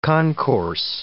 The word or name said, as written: concourse